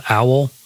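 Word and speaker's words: Owel: This is a band called Owl. I think that's how you pronounce it. It's O-W-E-L, all caps.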